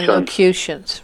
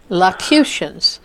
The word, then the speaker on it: locutions: That's it, low-Q. – Low-Q? – Yeah, locutions, right. – I only took 3 months to say it right. – You say it pretty close. Now you got it. Locutions.